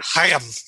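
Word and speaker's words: Haim: This song features backup vocals by Hayam. I'm always trying to pronounce that correctly. – Put a little more of a loogie in your throat as you say it. – Hayam. – There it is.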